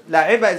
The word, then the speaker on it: لعبة: Now, if you pronounce لعبة is a تاء مربوطة, right? Mm-hmm. So if you're continuing, like saying لعبة الكرة, the football player, then you say لعبة الكرة, لعبة ال. Okay, لعبة.